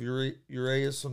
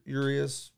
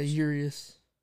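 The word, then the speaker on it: Urías: Musgrove's on the mound for the Padres, and you have Urias on the mound for the – or Urias, I think it's pronounced. – Yeah, Urias.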